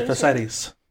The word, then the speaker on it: Thersites: We have Thersites? Is that how you say it? Yeah, Thersites.